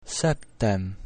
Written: septem

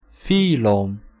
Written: phylum

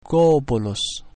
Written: golbulus

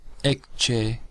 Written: ecce

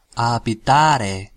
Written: habitare

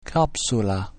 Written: capsula

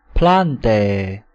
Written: plantae

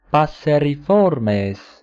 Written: passeriformes

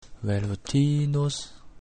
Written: velutinus

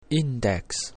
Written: index

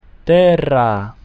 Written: terra

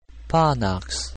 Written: panax

